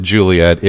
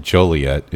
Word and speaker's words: Joliet: And, of course, they did that just in tribute to Shakespeare's Romeo and Juliet, which you don't pronounce it Juliet. It is pronounced Joliet. It is a French name.